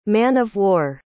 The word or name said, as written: man-of-war